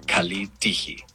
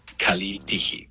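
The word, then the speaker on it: kalitigi (Καλή τύχη): En el griego moderno se dice kalitigi. Kalitigi. Pronunciación aproximada. Kalitigi.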